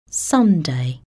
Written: Sunday

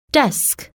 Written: desk

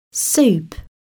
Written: soup

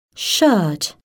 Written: shirt

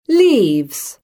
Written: leaves

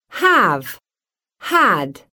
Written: have-had

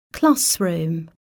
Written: classroom

